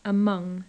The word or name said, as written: among